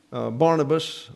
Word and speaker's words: Barnabas: Barnabas, or Barnabas is the way it's pronounced, son of exhortation.